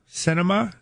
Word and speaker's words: Sinema: Another seat, the replacement for John McCain, McSally, a Republican, and Sinema, is that the proper pronunciation? – Yes.